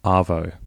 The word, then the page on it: arvo: Australian English